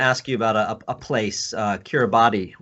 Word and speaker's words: Kiribati: So the island of Kiribati is how they pronounce it there.